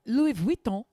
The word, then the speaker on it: Louis Vuitton: Louis Vuitton is how you say it.